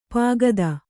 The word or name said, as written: ಪೋಕ್ತ